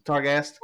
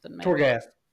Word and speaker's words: Torghast: I think it's Targast. – Torghast.